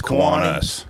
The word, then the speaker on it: Kiwanis: Did I mispronounce that? – It's Kiwanis. – Kiwanis. Kiwanis. – That's the way everybody else pronounces it.